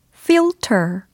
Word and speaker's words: filter: F I-L-T-E-R 이라는 철자로 이루어져 있고요. 이 사운드를 연습해 두시면 됩니다. 가 아니고 이렇게 시작이 돼요. 그래서 앞니 한 두 개 정도가 아랫입술을 꽉 물고 있으면서 발음을 연습하셔야 되겠죠.